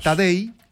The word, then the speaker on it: Tadej: Tadej, como Tadeo, pero Tadej Pogačar se escribe.